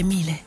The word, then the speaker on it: Miele: Si scrive Miele, si legge Miele.